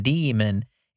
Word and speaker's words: daemon: So it says the word demon is an alternative spelling of D-A-E-M-O-N is an alternative spelling to demon. It's pronounced D-E-E-M-E-N, demon, in the context of computer software. The original pronunciation demon has drifted to daemon for some speakers.